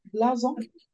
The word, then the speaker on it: blouson: And she says she wore a khaki blousen, is that how you say it? Blousen? Don't know. – Blouson.